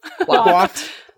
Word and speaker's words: coiffed: Quaffed. Quaff sounded a little dirty, so I didn't want to say it. – But that is how you pronounce it.